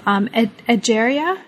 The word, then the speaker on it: Egeria: case of Egeria. Am I pronouncing that correctly? – Yeah, I love that.